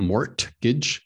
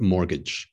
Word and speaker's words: mortgage: So the T is silent, not mortgage, but mortgage.